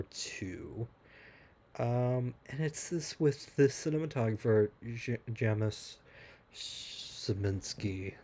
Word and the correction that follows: Janusz Kaminski: Let me pronounce his name properly. It's Janusz Kaminski.